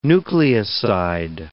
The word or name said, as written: nucleoside